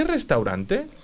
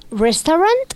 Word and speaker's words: restaurant: Esa AU en inglés, cada vez que vemos una AU al principio de una palabra o en el medio, no se dice AU en inglés, se dice O. Entonces, no se va a decir restaurant, sino se va a decir restaurant. – Restaurant. – Sí. Mira, Australia... Se escribe A-U-S, se escribe igual en inglés que en español, ¿vale?